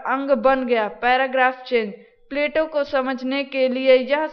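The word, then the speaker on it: Plato: प्लेटो उच्चारण अंग्रेजी के अनुसार है। इस शब्द का यूनानी उच्चारण प्लाटोन है, जो अरबी में अफलातून के रूप में प्रचलित है। इस पुस्तक में अंग्रेजी का ही उच्चारण रखा गया है, क्योंकि यूनानी उच्चारण रखने से पाठकों को अनावश्यक भ्रम होता है।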